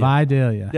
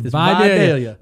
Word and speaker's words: Vidalia: Is it Vidalia or is it Vidalia? – It's Vidalia. – That's your onion. It's Vidalia.